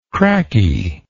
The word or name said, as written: cracky